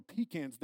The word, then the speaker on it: pecan: I'm from South Carolina. That is how it's pronounced. We have more pecans there than you hear. It is not pecan. It is pecan. There's no H in it, all right?